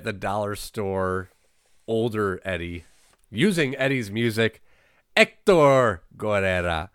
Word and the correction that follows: Guerrero: Uh, Guerrero, sorry, I said that wrong.